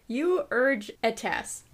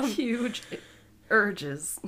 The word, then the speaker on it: Euergetes: The Macedonian pharaoh, and this I had to look up the pronunciation because it was a doozy, so the Macedonian pharaoh Ptolemy the Third, Euergetes. – Huge urges. – Yeah. No.